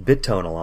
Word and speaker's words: Bitonal: I always called it Bitonal, and when I was chatting them up to get permission to use the music, they're like, it's actually pronounced Bitonal.